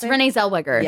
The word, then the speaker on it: Zellweger: Zellweger? Is that how you say it? – Yes, Renee Zellweger.